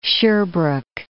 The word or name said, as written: Sherbrooke